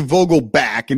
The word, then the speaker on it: Vogelbach: They were calling Vogelbach went to Vogelback and just said, well, you kind of mispronounced it forever.